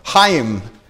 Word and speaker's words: Chaim: The second ministry is a PCA ministry called Chaim. I pronounced that wrong. It's a Hebrew word. Chaim. C-H-A-I-M.